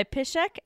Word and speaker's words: Piszczek: And I have no idea if this author is actually Polish, but it's Polish last name. So I'm going to pronounce it that way. And if they pronounce it differently, sorry. But it's written by Piszek and Pimputkar in 2020.